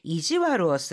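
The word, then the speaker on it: 悪: 스키나코니 좋아하는 아이한테 이지와르를 する 좀 일부러 못되게 구는 그런 아이들이 있었잖아요. 이마시타요네. 스키나코니 코 이지와르를 して 낚았다리 뭐 네, 시다리. 자, 이지와르 여기에서는 이 악자를 와르라고 읽고 있습니다.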